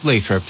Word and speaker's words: Lathrop: Is that how you pronounce it? – Yes. – Yeah, or is it Lathrop? – Lathrop, yeah. – Okay. We'll put a L-A-Y to it.